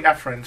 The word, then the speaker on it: Efren: That's always tell people who can't say my name, say E is your friend. Or people call me Efren.